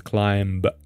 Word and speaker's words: climb: But often students pronounce the word as climb, climbing, and the B, letter B, doesn't represent a sound here, or it's not pronounced, is what I mean. So you don't say B, it's just M, climb.